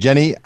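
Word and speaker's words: Jhené: This is Jean Aiko, I think is how she pronounces her name. – Jenny Aiko. – Jenny? Okay. Thank you.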